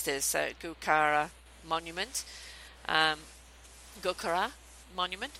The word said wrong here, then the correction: Gurkha: Oh, the Gurkha, yes. – Gurkha, sorry.